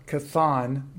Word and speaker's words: Chthon: It was written so long ago by uh, Chthon, not Cthulhu.